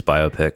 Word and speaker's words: biopic: Because afterwards, the next day I looked it up and I pressed the little pronunciation thing. And it's biopic. – Yeah, it is biopic.